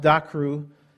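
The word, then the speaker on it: dakru (δάκρυ): One of them is based on the word for tear, pronounced dakru, and it brings to mind the image of a single tear rolling down the cheek.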